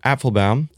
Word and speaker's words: Apfelbaum: It's Applebaum, A-P-F-E-L-B-A-U-M. So it's three syllables. – Cool. So I mean, meaning the F is not silent, basically. – Applebaum. It's not silent.